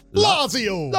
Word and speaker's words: Lazio: Italian club Lazio. Is that how you say it? – Perfect. Can you say that accent? – Nope. Okay. Italian club Lazio.